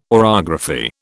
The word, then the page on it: orography: British English